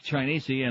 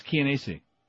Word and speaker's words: Chianese: The Columbus Citizens Foundation had sought a federal court order banning Bloomberg from bringing Dominic Chianese and Lorraine. I'm not pronouncing his name right, by the way. It's Chianese. – Yeah, sure. – That's what they said on the news last night.